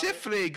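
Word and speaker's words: Softley: Ian Siffley. Gross. – You probably mispronounced that, but who cares? – It's Siffley, good sir.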